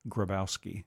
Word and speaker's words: Grabowski: Grabowski. – Yeah. Is that how it's pronounced? Grabowski? – I'm sorry? – How is it pronounced? – Grabowski. – Oh, sorry. I haven't said it right.